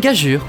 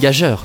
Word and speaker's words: gageure: Une gageure, oui, et pas une gageure. G-A-G-E-U-R-E, gageure. Déjà,